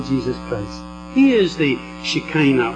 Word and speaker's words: Shekinah: And I found out that I can no longer actually say Shekinah, as I've done for 50 years, but I have to say now Shekinah, which is the Hebrew pronunciation I gather.